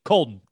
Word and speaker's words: Coldon: And I hope I'm saying that right too. C-O-L-D-O-N, Colden, gotta be. – That's right. That's right.